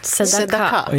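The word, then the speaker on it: Tzedaka (צדקה): Och det viktigaste hebreiska ordet, det är tzedaka. – Tzedaka? – Ja. Kanske vill uttala det lite bättre än vad jag gör.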